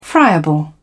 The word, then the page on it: friable: British English